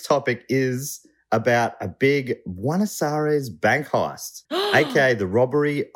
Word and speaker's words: Buenos Aires: Buenos Aires. – Yes, it is. Dave, can we get a pronunciation check there? – Buenos Aires.